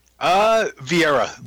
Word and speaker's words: Vieira: VIEIRA. Meredith VIEIRA.